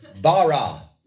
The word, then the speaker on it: bara: It is the Hebrew word bara. It's spelled B-A-R-A.